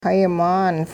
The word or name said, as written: hayi-maan